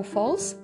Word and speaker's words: Foals: I cóż, tą piosenką jest piosenka zespołu Falls. Pisze się Foals.